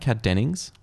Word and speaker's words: Kat Dennings: I think at one point Max is Kat Dennings. Am I saying that right? Kat Dennings.